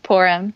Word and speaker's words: Purim: How do I say it? Purim? – Purim. Mm-hmm.